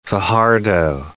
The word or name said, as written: Fajardo